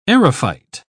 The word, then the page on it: aerophyte: British English